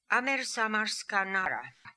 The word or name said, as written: Amersamarskanara